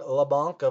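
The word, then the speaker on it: Leblanc: For my next forward streamer pick, we've got Kevin LeBanc of the San Jose Sharks. And first off, I know it's pronounced LeBanc, but it's a zillion times funner to call it LeBanc.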